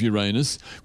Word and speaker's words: Uranus: The new publications editor says the fun paper that will include local news and promote the tourist town of Uranus, which is pronounced the way any self respecting class clown would say it.